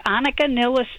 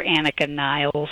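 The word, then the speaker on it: Anika Nilles: New drummer who is Annika Nillis. She is wonderful. You'll hear Annika Niles. I'm giving her the German pronunciation.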